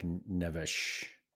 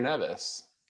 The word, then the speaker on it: Neves: Yeah, John, I mean, João Neves is really good, and he probably pronounces it like Nevesh.